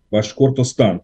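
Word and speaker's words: Башкортостан: Ваш Кортостан, правильно? – Все правильно.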